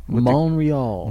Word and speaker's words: Montreal: You know how they say it there? – Montreal. – Montreal, which is weird because there's a big T in the middle.